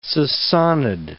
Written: Sasanid

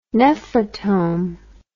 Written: nephrotome

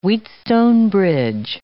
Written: Wheatstone bridge

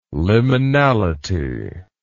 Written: liminality

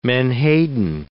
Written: menhaden

